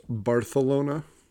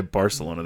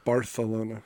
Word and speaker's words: Barcelona: Actually, also, did you know that it's pronounced Barcelona? – I did, because there's a restaurant in Clayton that that if you say it the other way, if you say Barcelona, they correct you.